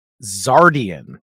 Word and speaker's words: Xardion: This is Zardian, and it's spelled with an X.